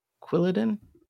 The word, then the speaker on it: Quilladin: And then they're gonna do the uh, the raid thing that they've continued to do, the four star raids uh, with Quilladin. Did I say that right? Quilladin.